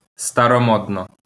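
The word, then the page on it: старомодно: Ukrainian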